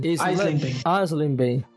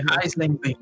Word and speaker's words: Eisleben: Lutero, ele nasceu em se eu estiver falando errado, me corrija, né? Eisleben, é isso mesmo? – Eisleben. – Oh, muito obrigado. – É Eisleben.